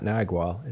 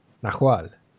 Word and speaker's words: Nahual: It's not Nagual. It's, Nahual, with an H. That's how you pronounce it.